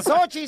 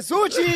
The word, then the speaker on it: sushi: No se dice Sochi, se dice sushi.